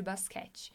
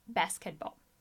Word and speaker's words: basketball: Para o jogo de basquete. Cuidado com a pronúncia, não é basquete, but basketball. Right?